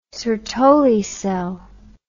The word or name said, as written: Sertoli cell